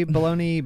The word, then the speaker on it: bologna: Can anyone say bologna burger in a Elliot Sadler accent? – It's bologna. It runs together.